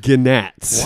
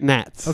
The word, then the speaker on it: gnats: Well, I know that would be gnats. Why are they worshiping? And I would have clearly pronounced the G. – Oh, okay. – But no, great gnats. – Okay. – N-A-T-S.